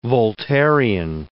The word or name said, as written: Voltairian